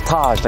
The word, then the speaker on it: Taj: All I'd always have to tell them, my name is Taj, like the Taj Mahal.